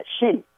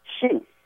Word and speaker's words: Chin: Thì ông này đó là họ Tần, Trung Quốc nó đọc là cái âm của tiếng Tàu đó, nó đọc là Xin, xin. Thành ra mấy nhà thừa sai đó họ mới âm, họ mới ký âm, viết là cái âm xin, là họ viết là mình đọc theo tiếng Anh nha anh Trần Anh, là C-H-I-N. Đúng lý đó, mình phải đọc là xin mới đúng.